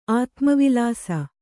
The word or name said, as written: ಆತ್ಮವಿಲಾಸ